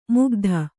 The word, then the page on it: ಮುಗ್ಧ: kannada